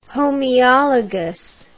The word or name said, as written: homoeologous